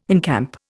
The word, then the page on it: encamp: Indian